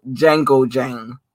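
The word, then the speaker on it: Django Jane: I just played y'all Django Jang, because that's how it's really pronounced.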